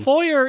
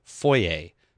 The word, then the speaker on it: foyer: But for me, and for the rest of Canada, at least, the word, and France, I guess, the word is foyer, not foyer, even though it's right. Spelled with an R on the end.